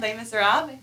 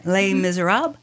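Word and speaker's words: Les Misérables: also known as Les Miserables. Is that how you say it? – Les Miserables. – Yeah, Les Miserables.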